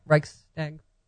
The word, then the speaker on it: Reichstag: Am I saying that right? Reichstag fire. – I believe so.